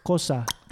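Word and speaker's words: Xhosa: So there's people who are from a group called Tkosa. Which that's how you say it. It's Tkosa. – Tkosa. – Or it's not, I think it's not a click.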